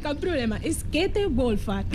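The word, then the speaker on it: Käthe Wohlfahrt: Com s'ha de pronunciar el nom d'aquesta botiga? Perquè hem tingut problemes a la redacció. – Bueno, cadascú ho pronuncia d'una manera diferent, però es diu Käthe Wolfart.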